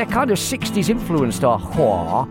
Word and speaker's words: HOA: Hwa. Spelled H-O-A, but pronounced Hwa.